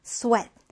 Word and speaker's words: sweat: 이거 sweet로 읽으면 안 됩니다. 자, 우리 sweet라는 단어는 이건 달콤한 이란 뜻이잖아요. 철자와 발음이 달라요. 자, 우리 이 단어는 어떻게 읽는다? sweat.